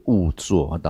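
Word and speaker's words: 恶作: 好，第一个是误作，导师这边有给一个读音呢，误啊。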